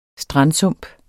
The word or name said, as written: strandsump